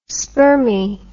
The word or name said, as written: spermi